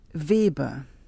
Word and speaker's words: Weber: It's Weber. – Can you spell that, please? – Sure. It's W-E-B-E-R.